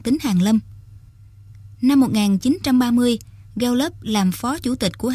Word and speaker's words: Gallup: Năm 1935, ông thành lập một viện mà sau này cả thế giới biết đến với tên là Viện Gallup, mà phiên âm tiếng Việt thì đọc là Gallup.